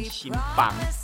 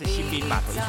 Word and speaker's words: 新浜: 冰对，因为因为呢，我每次都会，因为我的我的语音都会发发音成新邦，所以我老是会讲新邦。好，那其实它是新兵码头艺术空间。